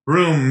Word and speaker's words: Bruttenholm: The scene that has stuck with me is this moment in Hellboy where Trevor Bruttenholm, the professor... – Pronounced Broom.